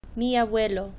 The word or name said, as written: mi abuelo